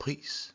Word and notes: praise: /pɹeɪz/; American English; [pɹeɪz]

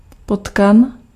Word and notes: rat: IPA: [ʁa]; French; France

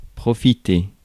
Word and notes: profit: /ˈprɒ.fət/; [ˈprɒ.fət]

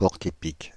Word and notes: porc-épic: /pɔʁ.k‿e.pik/

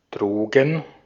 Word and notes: drugs: /dɹʌɡz/; [d͡ʒɹʌɡz]

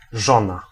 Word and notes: vaimo: /ˈʋɑi.mo/; /ˈvɑimo/

